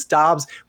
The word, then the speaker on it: Doubs: Derek, there is a controversy on how to pronounce Romeo's name. So maybe we just go with Romeo. Most people say Doubs. I believe it's Doubs.